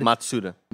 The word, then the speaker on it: Matsuda: At the start of the year, the president, Yosuke uh, Matasuda, sorry if I mispronounced that. I got pretty close on that. Yeah. Uh, said Matsuda. Matsuda, thank you.